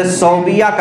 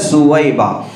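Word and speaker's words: ثویبہ: جن کو عام طور سے صوبیہ کہا جاتا ہے صوبیہ لکھنے میں صوبیہ دکھتا ہے لیکن حقیقت میں اس کا تلفظ ہے سویبہ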